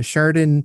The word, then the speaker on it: Chardon: I have the opportunity today to uh, interview Jake from the uh, Chardon Polka Band.